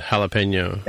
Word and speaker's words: jalapeno: I guess, So the jalapeno, which is spelled J-A-L, right, is pronounced with the H sound at the beginning, ha. So the J is H, but the... – Like Jaime, yeah. Uh-huh. – Right, like Jaime, right. But the habanero, which is spelled starting with an H, the H is silent.